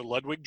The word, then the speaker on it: Ludwig: They did pronounce it Ludwig, so Ludwig drums.